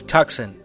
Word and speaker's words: Tucson: And if you're pronouncing that phonetically, it's Tucson.